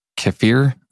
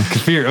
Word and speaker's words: kefir: Kefir. Okay, roll on. Anyway. – So I should just call it kefir because that's what people know it by, but it's actually pronounced kefir.